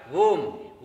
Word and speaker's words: Om: Pranava, om, om is a monosyllable, and it opens up into three syllables. A, u, ma. A, u, ma, three syllables.